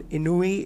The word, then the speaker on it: ennui: The next word is enjoin, E-N-J-O-I-N. It means to give orders to, enjoin. Inui is the next word. It is spelled as E-N-N-U-I.